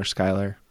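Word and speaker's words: Schuyler: That's how we know we're pronouncing his first name properly. – Right. – Smiler, Skyler, get yeah.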